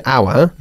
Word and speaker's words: our: Se pronuncia our, nuestro. – Our. – Okay, our, no our.